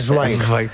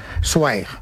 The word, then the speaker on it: Zweig: Por el camino bien, ahora hay que decir Zweig. – Zweig. – Zweig. Stefan Zweig. – Es así como se pronuncia en alemán.